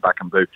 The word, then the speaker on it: Cedric Bakambu: And just finally, Cedric Bakambu, is that how I pronounce it? – Bakambu.